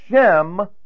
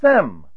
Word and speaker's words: Shem: You also have, oh, by the way, in that connection, I told you last time, sometimes Shem is pronounced Sem.